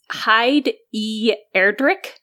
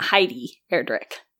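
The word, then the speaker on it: Heid E. Erdrich: It's Hyde E. Erdrick, but it's spelled H-E-I-D-E period. Erdrick. So E is like an initial. So it's not Heidi Erdrick. It's Hyde E. Erdrick.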